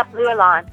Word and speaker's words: Bluelan: Did you say it was Blue Elon? – Yeah, Blue Elon. – With the accent on the A, I suppose. – Accent aigu on the E, actually. The Blue, B-L-U-E, and then, actually it's B-L-U. Sorry, B-L-U-E-L-A-N, okay.